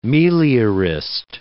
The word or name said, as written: meliorist